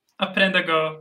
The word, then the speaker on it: Aprenda: Aprenda Go. The R is a lot softer.